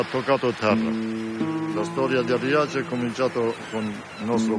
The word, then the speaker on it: Riace: Alors, comme beaucoup de petits villages, Rias, mais bon, je suppose que ça se prononce Riace, situé au sud de l'Italie, mourait à petit feu à cause de l'exode rural.